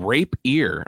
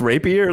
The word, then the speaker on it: rapier: Oh, I thought you said rape ear. I'm like, what the fuck's a rape ear? – Isn't it pronounced rapier, like the little sword thing? – Yeah.